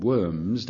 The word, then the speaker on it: Worms: Now, the next meeting of the Diet was due at a city called Worms, W-O-R-M-S, if you want the German, Worms, but I'm using English pronunciations, and so it became known as the Diet of Worms, which has given rise to lots and lots of jokes, of course.